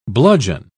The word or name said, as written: bludgeon